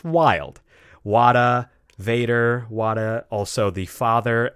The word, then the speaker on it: Wada: And Wada, in certain pronunciations, sounded a lot like Vader, which is also, as a quick side note, also where George Lucas got Vader from.